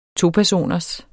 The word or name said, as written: topersoners